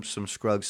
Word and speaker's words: Scruggs: Show me some Scruggs style.